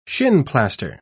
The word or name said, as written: shinplaster